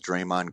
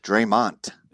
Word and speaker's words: Draymond: Coach Izzo calls Draymond Green Draymont. That's M-O-N-T. And that's his favorite player besides Mateen. – That's great. – Draymont. – Draymond. – I tell Draymond, all the coach, Draymond.